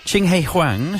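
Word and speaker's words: Ching He Huang: Chinghi Wang. No, Hung. Well, how'd you say it? – Oh, well, it was, It's on the back of that. – So I think I pronounced it correctly. – You pronounced it perfect.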